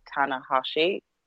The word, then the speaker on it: Tanahashi: And for me, it was the first match of seeing Tanahashi.